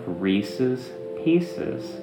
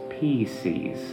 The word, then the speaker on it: Reese's Pieces: Which is exactly how you say the name of the candy bar. Which is exactly why they came out with Reese's Pieces. Because it rhymes. It's perfect. And you made it imperfect by thinking it was PCs.